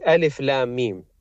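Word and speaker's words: Alif Lam Mim (الم): It's a word that is spelt differently to how it's read, which is Alif Lam Mim.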